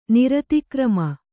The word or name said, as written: ನಿರತಿಕ್ರಮ